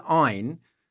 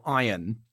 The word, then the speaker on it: Ayn: And so Ayn Rand, which is pronounced either Ayn, which works, Ayn, the German for one, or iron.